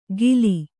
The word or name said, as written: ಗಿಲಿ